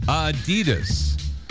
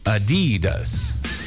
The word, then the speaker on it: Adidas: It's supposed to be Adidas, with a slight emphasis on the first syllable. Not Adidas, stressing the second.